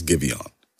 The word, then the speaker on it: GIVEON: Yes, I did look up how to say his name before the podcast because I didn't want y'all adding me. Okay, so leave me alone. It is GIVEON.